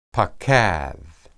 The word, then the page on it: paqad: Hebrew